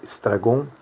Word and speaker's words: Estragon: E esses dois, um é o Estragon, né, na pronúncia francesa, Estragon, que é como nós vamos falar durante a aula, e outro é o Vladimir.